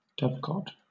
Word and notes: dovecote: British English

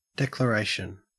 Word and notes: declaration: Australian English

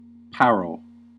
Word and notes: parol: American English

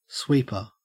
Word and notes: sweeper: Australian English

